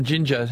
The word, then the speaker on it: Jinja: give us a ring from Uganda, from Jinja. How do you spell it? – I will. – It's not as in, not in the stuff you eat, is it? – No, it's J-I-N-J-A. J-I-N-J-A.